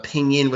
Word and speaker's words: pinyin: But they go based off the pinyin, which it's more like it's based off the English alphabetical system. So it's like, you know, P-I-N, pin. Y-I-N, ying, pinyin, right? I can pronounce it that way.